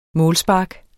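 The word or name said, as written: MÅLSPARK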